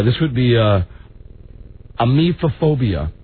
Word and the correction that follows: emetophobia: A mephophobia. – Emetophobia. Emetophobia. – Spell it. – E-M-E-T-O, emetophobia, P-H-O-B-I-A.